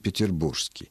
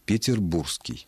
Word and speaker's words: петербургский: Современные словари уже сделали допустимым, не основным, я подчеркиваю, но допустимым, то есть не являющимся грубой ошибкой, вариант петербургский. Но каждый уважающий себя петербуржец все-таки должен отдавать себе отчет в том, что подлинно-литературное, абсолютно нормативное произношение этого прилагательного – петербургский. Пишется буква «г», а при произношении она просто выпадает.